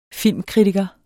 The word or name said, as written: Filmkritiker